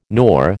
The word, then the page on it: nor: American English